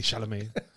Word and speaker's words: Chalamet: People pronounce his name. – How was that again? – Timothy Chalamet.